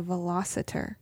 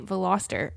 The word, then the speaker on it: Veloster: Mom tried to tell that it was a velociter. – Oh yeah, we had to argue. – Oh, she added in an extra syllable. Mm-hmm. – Yeah, and I was like, no, you're wrong. You're wrong. That's not what it's called. It's a Hyundai Veloster.